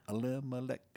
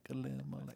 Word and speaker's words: Elimelech: I think if we could like all line up and do like The Lion Sleeps Tonight and do Elimelech, Elimelech. – Oh, nice. – Then Darren will never forget how it's pronounced again.